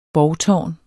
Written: borgtårn

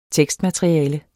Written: tekstmateriale

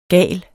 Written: GALESTE

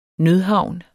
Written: nødhavn

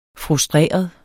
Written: frustreret

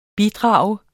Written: bidrag

